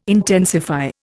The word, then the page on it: intensify: Indian